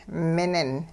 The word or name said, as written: minin